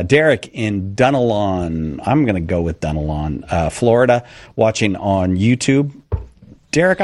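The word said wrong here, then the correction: Dunnellon: No, actually, it's pronounced Dunellen. – Dunellen. What? That seems to really amuse you, Derek, how badly I did on that. You seem genuinely delighted by that. All right, Dunellen, I'll remember It's okay.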